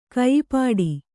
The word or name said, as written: ಕಯಿಪಾಡಿ